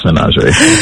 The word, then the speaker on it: menagerie: And they are doing the Glass menagerie. Did I say that right? – 100%. – To the best of my knowledge, yes, the glass menagerie.